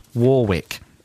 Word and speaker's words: Warwick: Here's a travel entail from Rory in Warwick that I'm going to pronounce Warwick.